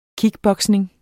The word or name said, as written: kickboxing